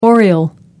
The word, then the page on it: oriole: American English